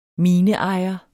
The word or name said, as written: mineejer